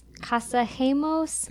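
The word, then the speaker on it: Casagemas: One of the people who was in that group was a guy named Carlos, I don't know how to say his last name, Casamos? Casagemos? – That's exactly Casagemos.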